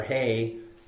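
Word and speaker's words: hey (ἤ): You see that little definition there, the Greek word, you can't really, it's a or hey is how it's pronounced.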